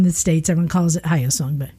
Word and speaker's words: Hyosung: The proper pronunciation that I found was Hyosung.